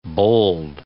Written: bowled

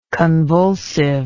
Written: convulsiveness

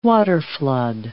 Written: waterflood

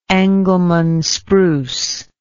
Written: Engelmann spruce

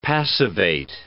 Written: passivate

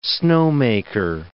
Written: snowmaker

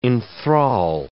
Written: enthral

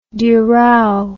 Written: dural

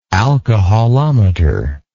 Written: alcoholometer

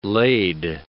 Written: laid

